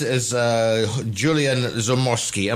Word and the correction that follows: Zoromsky: Am I there or thereabouts? Well, who is this gentleman? – You're very close. Julian Zoromsky.